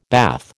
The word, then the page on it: bath: American English